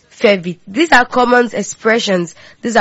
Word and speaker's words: fais vite: How do you say that in French? We say, fais vite. Fais vite, fais vite, fais vite, fais vite.